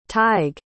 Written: tadhg

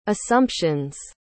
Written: assumptions

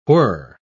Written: whir

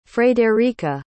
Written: frederika

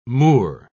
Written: moor

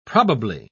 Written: probably

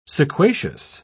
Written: sequacious

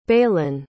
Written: balen

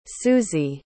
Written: suzy

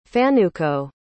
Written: fanuco

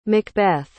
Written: macbeth

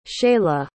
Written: Sheyla

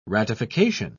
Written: ratification

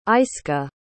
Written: ayska

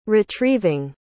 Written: retrieving

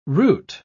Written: route

